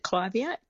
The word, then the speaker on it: Clivia: So first of all, I pronounce it Clivia, but it is a tomato.